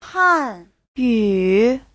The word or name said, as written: han4yu3